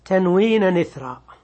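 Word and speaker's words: إثرا: وكلمة إثر لا تنطق على أنها همزة قطع، وإنما تنطق بنقل كسرة الهمزة إلى النون الساكنة قبلها، فتكون تنوين نثرا، تنوين نثرا، أي اجعل التنوين بعد الفتح ألفا، أي قلبه ألفا.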